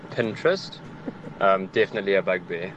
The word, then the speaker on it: Pinterest: For me, it definitely has to be Pinterest. When people pronounce it Pininterest, the word reads Pinterest.